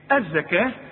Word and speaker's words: الزكاة: وآتوا الزكاة، الزكاة بتكتب بالواو دائما في القرآن وتنطق بالألف، نحول الواو إلى إيه؟ ألف. الزكاة، خلاص؟